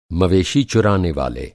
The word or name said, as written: मवेशी